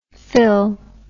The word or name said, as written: phyll